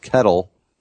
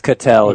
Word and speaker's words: Ketel: I picked up, now I don't, is it Ketel Marte? Because I've also heard it pronounced Kettle. – It's Ketel, yes. According to Baseball Reference.